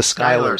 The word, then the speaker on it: Schuyler: The sh- the sh- Sky- sh- Schuyler, the Schuyler, Schuyler sisters.